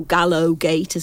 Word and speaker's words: Gallowgate: And Gallowgate, or Gallowgate as it's really supposed to be pronounced, is the area where the gates of execution were, so the gallows.